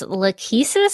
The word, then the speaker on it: Lachesis: Yeah, Emma says it's pronounced Lachesis, so I guess we'll go with that.